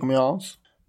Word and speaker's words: Amiens: 530 brittiska och 70 franska pansarvagnar är som koncentreras vid staden Amiens. – Mm. – Rätt uttalat? – Ja. På svensk-franska så är det väl det.